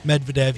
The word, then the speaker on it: Medvedev: He was down 2-0 to Daniel Medvedev. I believe that's how you pronounce it. – Medvedev, yep.